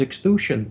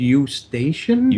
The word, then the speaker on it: Eustachian: I used to pronounce it as extusion tubes. – As a note, he's not a medical doctor, but seems to be more briefed on this topic than any of us would have been. – It's pronounced eustachian.